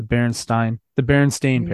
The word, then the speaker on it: Berenstain: The Berenstain pairs. Berenstain, like stain, as you stained a wall or stained paint.